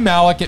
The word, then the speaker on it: Malek: Rami Malek. It rhymes with ham hock. Hammock. Hammock. – Pammy mammock. – Hammock. – The hammock. – Lammy stammock. – Banana hammock. – Yes.